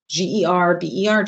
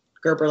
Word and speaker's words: Gerber: Gerber, just like the baby food. So Alyson, Gerber, like the baby food.